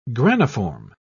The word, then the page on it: graniform: British English